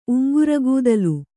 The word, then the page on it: ಉಂಗ್ರಗೂದಲು: Kannada